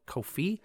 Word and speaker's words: Ko-fi: Ko-fi, is how I'm pronouncing it, K-O-F-I.